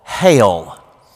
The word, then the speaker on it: Geel: a small village in Belgium called Hale. Now, if you're looking for it on a map, it's not spelled like you would think. It's spelled G-E-E-L, but it's pronounced Hale, Hale, Belgium.